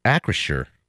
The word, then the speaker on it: Acrisure: Acrisure. Acrisure. – Hmm. Okay. Um. A Chrysler. I don't know how you say it, Ac- but it's A C R I S U R. Acrisure. I think you got it.